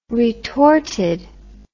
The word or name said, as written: retorted